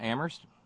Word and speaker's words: Amherst: Thanks very kindly to Terry up in Amherst, where only the H is silent.